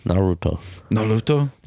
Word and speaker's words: Naruto: Naruto.